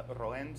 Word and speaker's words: Roen: Okay, it's Roen Salinas, R-O-E-N-S-A-L-I-N-A-S.